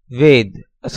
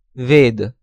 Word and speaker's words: Veda: Usual transliteration, V-A-R-U-N-A. Sanskrit pronunciation, Varun. Hindi pronunciation, Varun. Foreigner's pronunciation, Varuna. Next row, Hindi-Sanskrit word, Ved, a scripture. Usual transliteration, V-E-D-A. Sanskrit pronunciation, Ved. Hindi pronunciation, Ved. Foreigner's pronunciation, Veda. Next row, Hindi-Sanskrit word, Ram, a hero. Usual transliteration, R-A-M-A or R-A Macron, M-A.